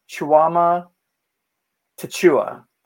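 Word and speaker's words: Tchamwa Tchatchoua: And then off the bench, you get a nice game from, I always get this guy's name wrong, Jonathan Chuama Tachua. That's yep. right? Uh, yeah, of course.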